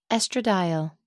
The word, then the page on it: estradiol: American English